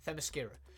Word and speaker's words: Themyscira: I, I, I always butcher the name of this place. I think it's Themyscira, right? Themyscira.